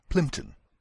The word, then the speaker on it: Plympton: Ici, il fallait noter l'accent sur la première syllabe de Reynolds, juste avant le R, le mot born, juste avant le B, et la première syllabe de Plympton, juste avant le premier P.